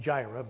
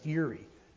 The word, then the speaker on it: Yireh: Some people pronounce it Jireh, but it's Yireh.